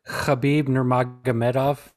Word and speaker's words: Khabib Nurmagomedov: First one we had was Khabib Nurmagomedov.